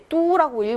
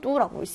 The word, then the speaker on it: 都: 난징이란 도시는 중국 구도, 예, 뚜라고 읽어야죠. 수도 할 때도, 고도 할 때도 뚜라고 읽습니다.